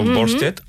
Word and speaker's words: Volstead: I llavors tot això, sí, dona cabuda a aquesta llei Volstead, no sé si mai si ho pronuncio bé. – Volstead, sí. Sona bé.